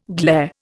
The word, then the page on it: glair: Indian